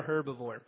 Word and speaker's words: herbivore: A carnivore, not a herbivore. Or I guess the H is silent, whatever.